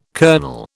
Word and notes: kernel: British English

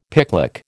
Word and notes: picklock: American English

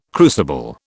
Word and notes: crucible: British English

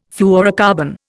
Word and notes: fluorocarbon: Indian

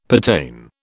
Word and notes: pertain: British English

